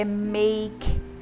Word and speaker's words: make: Prestando muita atenção que como nós falamos português e temos uma língua silábica, nós vamos ter a tendência de falar make, tá? E é make. A vogal do final, ela não é pronunciada, né? Nós pronunciamos até a consoante make.